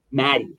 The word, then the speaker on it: Matty: Maddie, M-A-T-T-Y. Maddie, yeah. Pronounced as though there's two T's, but yeah, it's like Croatian originally or something. It sounds like it should be the pilot term, Maddie, but no, it's Maddie.